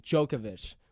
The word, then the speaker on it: Djokovic: Djokovic with a D, silent D.